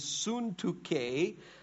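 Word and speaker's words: Syntyche: And he says this, he says, I plead with you, Odia, and I plead with, the way you pronounce her name is Suntuke, to agree with one another in the Lord.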